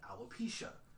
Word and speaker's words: alopecia: So in 2018, Pickett, uh, Jada Pinkett Smith revealed that she was diagnosed with alopecia a condition that causes hair loss.